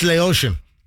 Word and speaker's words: Laotian: Laotian. It's Laotian. From Laos.